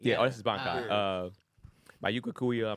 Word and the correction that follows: Byakuya: I'm saying that wrong again. – Byakuya and Renji collapse, and they are in critical condition.